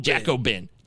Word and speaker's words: Jacobin: Jacobin. Jacobin. It's like Bobin.